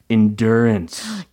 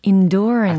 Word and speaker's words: endurance: endurance. – endurance. – I think Constantine has a lot of endurance for his love. 그러네요. – 예, 참을성 혹은 인내력이라는 의미가 되겠습니다. 이게 영국식으로는 endurance라고 발음을 하는 경향이 있더라고요.